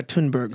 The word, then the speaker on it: Thunberg: Is that how you pronounce it? Thunberg? – Yeah, Thunberg.